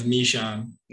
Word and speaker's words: Michon: Is that how you pronounce your last name? – No, it's Michon.